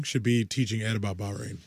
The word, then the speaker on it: Bahrain: It says you're in Bayron. – And there's no such thing as Bayron. Bahrain.